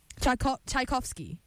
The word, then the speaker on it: Tchaikovsky: Wow, that's amazing, Chuck Schof. Whoa. No, I gotta pull up the pronunciation. Hold on. No one say anything. Tchaikovsky and Solitude.